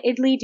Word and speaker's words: idli: And have you ever had an idli? Do you know what an idli is? – No. What is it? How do you spell it, first of all? – It is spelled I-D-L-I, idli.